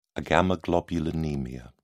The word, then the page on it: agammaglobulinemia: British English